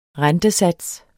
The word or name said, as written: RENTESATSEN